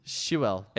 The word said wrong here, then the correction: Sewell: S-O-O-L is how you pronounce it. Sewell.